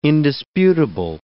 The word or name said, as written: indisputable